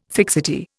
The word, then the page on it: fixity: Indian